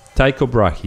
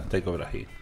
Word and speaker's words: Tycho Brahe: T-Y-C-O Brahe, B-R-A-H-E. Tycho Brahe. – Ahí está, Tycho Brahe.